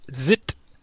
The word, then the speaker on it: ZZT: I think most people say ZZT, um, yeah. but technically it's pronounced Zit.